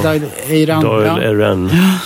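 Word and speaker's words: Dáil Éireann: Men den 21 januari 1919 konstitueras då det irländska parlamentet som heter någonting som jag inte vågar uttala. – Doyle. – The Doll, ska jag säga. – Ja. – Doyle Erin.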